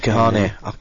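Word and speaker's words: Kahne: Oh, I haven't chosen Casey Kane yet, have I? – No, Casey Kahane. You've got to pronounce that correctly. It's an embarrassment when you get it wrong on the radio.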